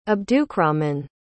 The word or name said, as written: abdukrahman